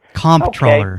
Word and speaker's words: comptroller: You pronounce the P. – I will report you. – The P is not silent. – Beg your pardon? – The P is not silent. It's comptroller.